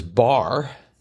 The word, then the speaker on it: barre: Nine across, exercise that incorporates ballet, yoga, and Pilates was bar. – Spelled B-A-R-R-E. – Thank you very much. – Yes. – I'd worked so hard on the pronunciation, I forgot about the spelling.